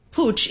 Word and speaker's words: Puig: Oh, and by the way, you do pronounce it Pooch, even though it's spelled P-U-I-G.